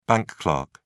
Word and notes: bank clerk: British English

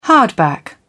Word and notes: hardback: British English

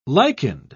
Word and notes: lichened: British English

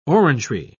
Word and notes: orangery: British English

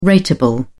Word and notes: ratable: British English

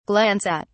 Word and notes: glance at: British English

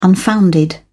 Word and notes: unfounded: British English

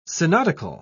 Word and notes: synodical: British English

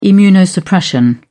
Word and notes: immunosuppression: British English

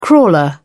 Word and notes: crawler: British English

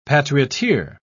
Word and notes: patrioteer: British English

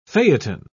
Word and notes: phaeton: British English

